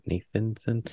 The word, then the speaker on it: Nathanson: Na- Nathan, did I say it right? Nathanson. Mm-hmm. But like literally yesterday, like I listen, I love listening to him in the fall.